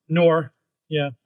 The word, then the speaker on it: Noir: And the next one is Spider-Man Noir. Is that how you pronounce it? – Noir, yeah.